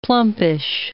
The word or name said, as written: plumpish